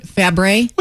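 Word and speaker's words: Fabares: Am I saying her name right? – Fabre? – Whatever. – I think, yeah, the S is silent.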